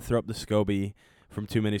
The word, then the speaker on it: SCOBY: And it's spelled S-C-O-B-Y. Yeah, SCOBY. Not Scooby, SCOBY.